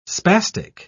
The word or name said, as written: spastic